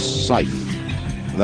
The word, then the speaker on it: Seife: Coming now, Charles Seife. That's S-E-I-F-E, but pronounced Seife, I believe.